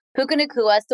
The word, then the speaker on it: Puka Nacua: Puka Nacua. Did I say it right? – You got it. Perfect.